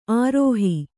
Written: ಆರೋಹಿ

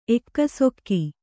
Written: ಎಕ್ಕಸಕ್ಕೆ

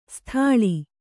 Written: ಸೀತಳ

